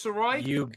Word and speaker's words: Sirois: Please welcome George Sirois. Did I pronounce that correct? George Sirois? – You sure got it.